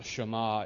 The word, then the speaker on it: Shema: The Shema, of course, pronounced Shema, is probably the one aspect of Judaism with which all Jews identify.